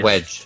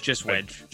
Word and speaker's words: Wedge: Wedgie Antilles, is am I saying that right? Because I don't watch Wedge. – Just Wedge.